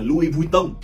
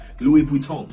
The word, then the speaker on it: Louis Vuitton: người Việt hay đọc là Louis Vuitton nhưng thực ra cũng không sai quá các bạn chỉ cần đọc nghe nó hơi pháp một chút là được Louis Vuitton Louis Vuitton Louis